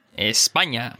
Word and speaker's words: España: Now, for those of you who are looking at the lesson notes, it's the N with a squiggle above it that makes a ñ sound, right? And you can start a syllable with it. – Mañana, España.